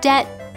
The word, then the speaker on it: debt: And words of Latin origin like debt, doubt, sign, receipt, indict, and aisle had letters added, not to be pronounced, but just as a reminder to readers where those words originally came from.